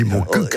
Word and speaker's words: Montcuq: Il faut prononcer le Q à la fin. Reportage. – Oui, comme ils disent, on dit un coq, on ne dit pas un co, donc on dit mon coq.